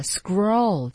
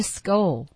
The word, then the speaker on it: scroll: 对，他就是说，你看有些地方像那个 scroll 这个词，它不是 skull 啊，像那个 warrior 这个词不是 warrior，就是你有一个习惯呢，喜欢用自己的这个发音的声音去代替那个肌肉和嘴唇和牙齿的这个运动。